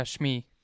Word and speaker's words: Shmi: Yeah, Shmi.